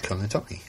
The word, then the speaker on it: Colantoni: How do you say Enrico? I know Enrico really well. It's Colantoni. Colantoni sounds right.